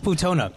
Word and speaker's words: Plutonia: It's Plutonia.